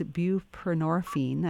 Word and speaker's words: buprenorphine: Is it buprenorphine? – Buprenorphine, yes.